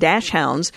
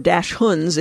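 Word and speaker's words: dachshunds: Instead, think dash hounds or dash huns is the right way to say it.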